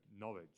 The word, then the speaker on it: Norwich: So, Norwich. – Norwich. – Yes. – Oh. First time it sounds like knowledge, you know, someone who has knowledge.